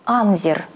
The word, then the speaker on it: Анзер: Мой знакомый работал сторожем на острове Анзер на Соловках. Он говорил, что в названии острова нужно делать ударение на первом слоге.